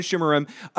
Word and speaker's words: Shimerman: And Armin Shib- Armin Shimerman, uh, amazing on Buffy and other things he's been in as well.